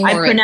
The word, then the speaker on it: Morin: And it's M-O-R-I-N. – Yes. – Amy Marin, M-O-R-I-N. – I pronounced it completely wrong because there's a singer, Maren Morris, and for some reason, that's how I connected the two of you.